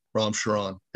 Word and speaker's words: Ram Charan: I'm going to try to pronounce his name. He is pronounced by Ram Charan, I think is how it's pronounced. – I looked that up. Right. Yeah. Ram Charan.